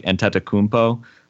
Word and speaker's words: Antetokounmpo: I mean, it's like this is like the big debate, and I don't think Giannis even really cares that much because he pronounces it phonetically, like Antetokounmpo, which is sort of like actually how they originally had the media guide had it, and then it went to Adetokounmpo, which like is his parents' like original name before it was, you know, Greekified or whatever.